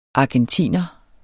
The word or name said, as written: ARGENTINERE